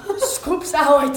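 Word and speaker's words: Scoops Ahoy: Como é que se pronuncia a Scoop Arroi? Scoops Arroi, tá.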